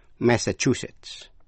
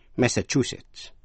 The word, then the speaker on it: Massachusetts: Massachusetts, Massachusetts, Massachusetts, nhấn mạnh vào vần thứ ba.